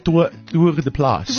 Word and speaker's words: Tour de place: And then Martin Bester se tour de place. Is that how you say it? – Ja, ja. Okay. Ja, tour de place.